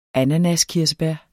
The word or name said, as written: ananaskirsebær